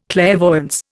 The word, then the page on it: clairvoyance: Indian